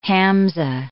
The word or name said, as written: hamza